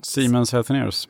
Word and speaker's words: Siemens Healthineers: Siemens Healthineers.